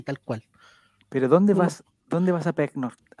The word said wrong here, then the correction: pernoctar: A Pecnor. ¿Cómo se dice? Pernoctar.